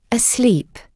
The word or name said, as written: asleep